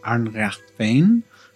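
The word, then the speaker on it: Ár nDraíocht Féin: Yes, I am also the treasurer for ADF, which is pronounced Arnrechtvein.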